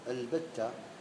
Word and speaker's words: البتة: هم يقولون البتة همزة وصلية لكن تنطق، البتة هي الهمزة الوصلية الوحيدة التي تنطق ده ابتداء ودرجة.